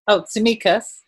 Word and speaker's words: Tsimikas: Tsimikas.